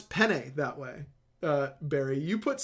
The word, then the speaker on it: penne: Penne. It's like, it's penne. – Yeah, penne. It's more, the first syllable is the emphasis.